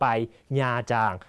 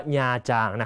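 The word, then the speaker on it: Nha Trang: ญาจางคำนี้ต้องอ่านให้ถูกนะฮะอ่านว่าญาจางนะครับเป็นสถานที่ท่องเที่ยวเวียดนามเป็นทะเลที่สวยสุดในประเทศเวียดนามนะครับคนไทยชอบไปอ่านว่านาตรังนะฮะไม่ใช่นะต้องญาจาง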